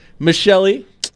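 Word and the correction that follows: Michel'le: Man, listen. – Is that right? – Listen, Michelle A.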